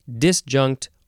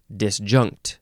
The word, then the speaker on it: disjunct: The next word, the second form of disjunct, and you can emphasize either syllable on this one, disjunct or disjunct.